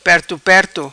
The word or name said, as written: Perto Perto